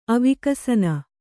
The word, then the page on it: ಅವಿಕಸನ: avikasana